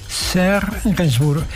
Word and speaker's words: Serge Gainsbourg: Serge Gainsbourg, perfecto.